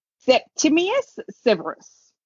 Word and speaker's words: Septimius Severus: Septimius Severus.